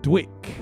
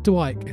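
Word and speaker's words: DWYCK: Tribe Called Quest, Oh My God, and Gangstar, the all-time classic Dwick. Not Dwight, as I've been pronouncing it for ever since that record came out. I've been playing it. I was watching the DJ premiere. So What's Up, where he explains some of the history behind his classic tunes, and he says that's pronounced Dwick.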